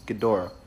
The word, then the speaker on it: Ghidorah: Gojira, Ghidorah. I'm very sorry, my nerd friends. It is Ghidorah.